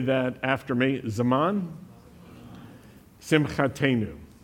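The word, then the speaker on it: Zaman Simchatenu (זמן שמחתנו): When a word in Hebrew ends in the new sound, N-double-O or N-U, long U, it means our. And so it's Zaman Simchatenu, the season of our joy.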